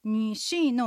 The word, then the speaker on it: 女士: 女士の女はうの口の形でにと発音します。日本語にはありませんね。で、下がって上がるアクセント、三声のアクセントです。士は舌先を歯の裏側に軽くつけたままシと発音します。アクセントは 4 声で下がります。